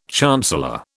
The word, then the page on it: chancellor: British English